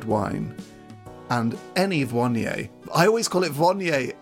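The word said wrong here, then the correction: Viognier: and you've pronounced it correctly, and now it's... How is it again? – Vionier.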